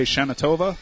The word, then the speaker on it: Shana Tova: What do you say, Shana Tova? – Shana Tova. – Shana Tova. Shana Tova. I put the different emphasis on the wrong syllable.